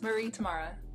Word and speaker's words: Marie Temara: Yes, Marie Temara.